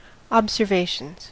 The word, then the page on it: observations: American English